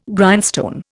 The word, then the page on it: Grindstone: Indian